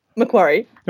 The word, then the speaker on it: Macquarie: And this is on the shores of Lake Macquarie, right? – Macquarie.